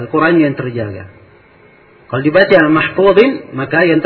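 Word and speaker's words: mahfuzin (مَحْفُوظٍ): Ini dibacaan kebanyakan qurra dibaca mahfuzin. Dan Nafi membacanya fi lauhin mahfuzun. Dibaca dengan dhamma.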